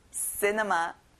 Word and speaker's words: cinema: 영화관, 극장, cinema. 친구들 앞에 C가 있는데 크 소리가 안 나요. 그리고 C에 강세를 넣어볼까요? Cinema. Very good.